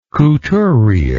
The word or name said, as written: couturiere